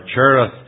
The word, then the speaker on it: Cherith: And at this brook, Cherith, or Cherith as we more commonly pronounce it, and I'll submit to convention for the sake of ease, the brook Cherith.